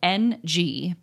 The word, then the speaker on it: Ng: It's spelled N-G, but it's pronounced Ng, like I-N-G.